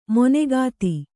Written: ಮನೋಗತ

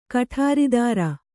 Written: ಕುಠಾರಧರ

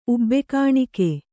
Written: ಉಬ್ಬೆಕಾಣಿಕೆ

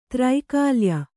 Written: ತ್ರೈಕಾಲ್ಯ

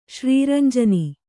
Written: ಶ್ರೀರಂಜನಿ

